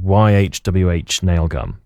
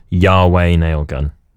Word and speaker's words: YHWH Nail Gun: Got a tune here from a band called, well, I thought they were called YHWH Nail Gun, but I looked up and it actually pronounced Yahweh Nail Gun.